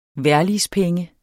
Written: vejrligspenge

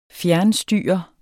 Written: fjernstyre

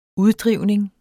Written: uddrivning

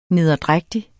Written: nederdrægtig

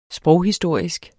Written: sproghistorisk

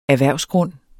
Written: erhvervsgrund

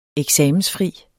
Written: eksamensfri

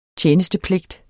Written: tjenestepligt

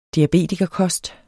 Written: diabetikerkost

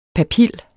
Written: papil

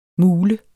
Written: mule

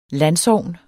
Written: landsogn

